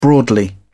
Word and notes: broadly: British English

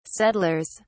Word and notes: settlers: British English

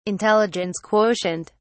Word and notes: intelligence quotient: British English

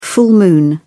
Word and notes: full moon: British English

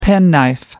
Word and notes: penknife: American English

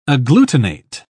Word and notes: agglutinate: British English

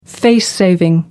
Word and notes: face saving: American English

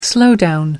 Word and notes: slowdown: American English

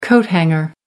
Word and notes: coat hanger: American English

